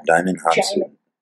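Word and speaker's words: Djimon Hounsou: Jaimin Hansu.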